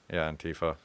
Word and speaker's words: Antifa: I'm not sure if I'm pronouncing Antifa. – This right. That's how I pronounce it. I wasn't sure Antifa.